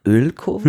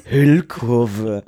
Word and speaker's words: Hüllkurve: Was? Ölkurve? – Hüll. – Achso. Hüll. Fachbegriff, erkläre ich dir nachher irgendwann. Benutzt nicht solche komischen Worte. Hüllkurve. Schreibt man das mit Ö oder mit Ü? – Ü. – Hüll? – Hüll. – Wie Hülle? – Ja.